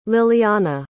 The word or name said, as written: lillianna